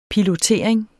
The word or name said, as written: pilotering